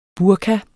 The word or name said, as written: burka